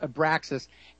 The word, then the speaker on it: Abraxas: Anyway, I want to talk a little bit about something called Abraxas. Arbaxas, I'm sorry. No, it's spelled wrong in this one piece. It's called Abraxas, and this is a company in Northern Virginia that was put together and is staffed by some of America's top intelligence people.